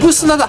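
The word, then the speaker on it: 産土: 産砂だ。そうだ、産道じゃなくて産砂って読むんですね、これ。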